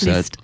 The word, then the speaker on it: Liszt: Liszt, I'm not sure how exactly you pronounce Liszt.